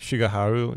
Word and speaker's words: Shigeharu: Now, I got to get this name right. Shigeharu? – I think Shigeharu, yeah.